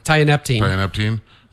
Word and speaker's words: Tianeptine: Tianeptine, nicknamed the gas station heroin.